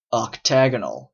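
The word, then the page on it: octagonal: Canadian English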